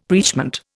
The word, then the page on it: preachment: Indian